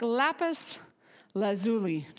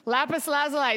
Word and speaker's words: lapis lazuli: Their appearance like lapis lazuli. Did I say it right? No. Lapis lazuli? Lazuli. Okay, sorry. I practiced that and I got it wrong. Lapis lazuli.